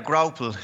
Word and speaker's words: graupel: Graupel. Can we expect graupel? Am I even pronouncing that right? – Yeah, graupel.